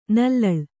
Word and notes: ನಲ್ಲಳು: nallaḷu

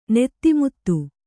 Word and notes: ನೀತಿಮತ್ತೆ: nīti matte

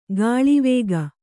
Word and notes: ಕೋಳ್ವೋಗು: Kannada

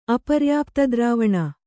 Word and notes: ಅಪರ್ಯಾಪ್ತದ್ರಾವಣ: Kannada

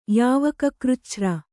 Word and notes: ಯಾವಕಕೃಚ್ಛ್ರ: yāvaka křcchra

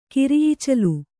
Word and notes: ಕಿರಿಈಚಲು: kiri īcalu

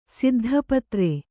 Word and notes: ಸಿದ್ಧಪತ್ರೆ: siddha patre

